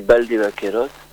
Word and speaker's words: Valdevaqueros: En espagnol, je pense que ça se dit Valdevaqueros. Bah, le B, tu sais, le V et le B, mais je pense que tu le prononces très bien.